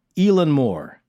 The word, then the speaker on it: Eilean Mòr: And also, I tried to get the pronunciation right, but somebody who's actually Scottish, Louise Palmer, told us Elan Moore.